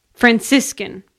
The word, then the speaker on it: Franciscan: How do you say that? Francescan? – Sure. – I seriously meant to look that up before doing this, and then I just never did. – Franciscan. – Okay, can I do that over? – Like San Franciscan.